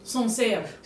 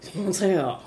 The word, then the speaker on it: Sancerre: Sancerre. – Sancerre.